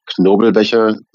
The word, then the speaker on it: Knobelbecher: I like Knobelbecher better. – Yeah. – Knobelbecher. – Knobelbecher? – Yeah.